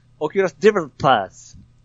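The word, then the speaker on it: Oculus Developers: 最後 S ついてるでしょ？ – デベロッパーズ。 – うん。そう、いい発音で言ってください。 – デベロッパーズ。オキュラスデベロッパーズ。 – なんか舌をなんかするっていうことは分かってんよね。 – 巻くんすよ。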